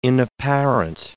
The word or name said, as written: inapparent